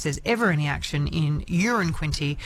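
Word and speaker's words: Uranquinty: It was Tambourine Bay Road, and I love this because Peter's texted in saying, I live in Urine Quinty. It's the same pronunciation as a number one, so it's not a big town.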